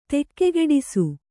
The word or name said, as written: ತಕ್ಕುಗೆಡಿಸು